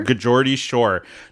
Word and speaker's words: Geordie Shore: The Geordie Shore? – Geordie Shore.